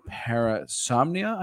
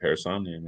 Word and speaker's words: Parasomnia: It's called Parasomnia. I think it's I didn't butcher that. I didn't butcher that, right? – No, you got it. Parasomnia, man.